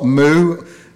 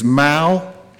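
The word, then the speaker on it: Mouw: I mispronounced his name. It is not Moo, it is Mao, although it still rhymes with cow.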